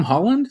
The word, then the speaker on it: Holland: Director Tom Holland. Is that how you pronounce it, Laura? Mm-hmm. Holland? Like Spider-Man Tom Holland? – Yeah.